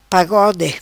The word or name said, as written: pagode